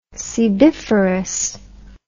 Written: sebiferous

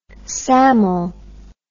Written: samel

